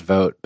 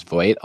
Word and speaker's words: Vogt: my last name is Vogt, but if I'm somewhere like a car rental place, people mispronounce it as Voight a lot of the time.